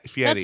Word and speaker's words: Fieri: Guy Fieri. – Fieri.